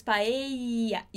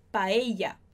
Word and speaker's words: paella: A gente faz esse izinho com a sua língua, com a pontinha da língua encostada aqui atrás dos dentes. Paella, ia, ia, ok? Paella, perfeito. A segunda forma de pronunciar esses dois L's é como se fosse o DJ na palavra jazz.